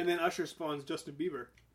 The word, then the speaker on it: Bieber: Bieber.